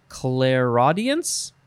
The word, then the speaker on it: clairaudience: It is, I guess you would pronounce it, clairaudience? Clairaudience. So it's clair, C-L-A-I-R, and then the word audience, all one word.